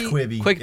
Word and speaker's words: Quibi: Go follow Quibi on I don't even know if I'm saying it right anymore. Quibi? – It's Quibi. It's Quibi.